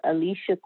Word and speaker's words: Alicia: I have a Facebook page as well, specific to real estate, and it's my name, Alicia Queen. First name is spelled A-L-I-C-I-A.